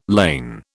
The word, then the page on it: ln: British English